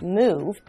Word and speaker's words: move: If we focus on the word move, it's a very special case because it's spelled similarly to love, L O V E but it's a different sound. It makes a o o sound like a cow, moo.